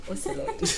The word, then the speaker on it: Ocelot: O-C-E-L-O-T. I don't know how to pronounce it. – It sounds like the animal. – It does sound like the animal. – Say the animal.